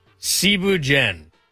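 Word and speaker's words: thieboudienne: First off, Cebu Jen. It's spelt like Thibu Dien.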